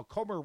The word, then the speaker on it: Comer: Comer, rhymes with Gomer,